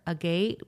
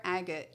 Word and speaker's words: agate: You have something on here that I'm reading, flower agate. What is that? – Flower agate. – Yeah. Agate, sorry. – No, it's okay. I didn't know how to say it.